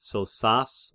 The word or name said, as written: Sosas